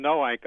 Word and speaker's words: Noank: This is in, let me pronounce this right, Nunk, Connecticut? Hello? – Yes, hi. – Go ahead. – This is in Noank.